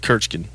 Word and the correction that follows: Kirchen: No, you didn't. – You had it right Kirchen. The first time.